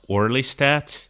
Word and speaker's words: orlistat: Yeah, a funny one that I saw is, I believe it's pronounced orlistat, which...